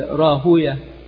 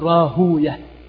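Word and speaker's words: راهويه: المحدثون يقولون ويه شيطان، وأكثر الناس ينطقون راهوية راهوي، وهكذا في نظائرها يقولون إسحاق ابن راهوي. والمحدثون يقولون كما ذكر ذلك الحافظ السيوطي في التدريب إن المحدثين يكرهون ويه، يقولون ويه شيطان، فلا ينطقونها هكذا، لا يقولون راهوي، إنما يقول راهوية، ينطقها هكذا لكراهتهم للشياطين ولكراهتنا له أي للشياطين أيضا.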